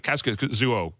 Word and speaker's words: Kaskisuo: Like I would think of Kaskisuo. Did I just pronounce that right? – Close enough.